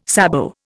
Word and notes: sabot: Indian

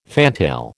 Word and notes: fantail: American English

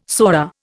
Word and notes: sora: Indian

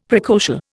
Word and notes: precocial: Indian

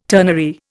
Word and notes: ternary: Indian

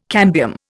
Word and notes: cambium: Indian

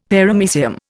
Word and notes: Paramecium: Indian

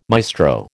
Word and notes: maestro: American English

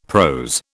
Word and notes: prose: British English

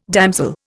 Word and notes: damsel: Indian

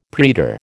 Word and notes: praetor: American English

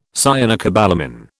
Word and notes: cyanocobalamin: British English

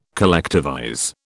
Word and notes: collectivize: British English

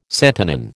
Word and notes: santonin: American English

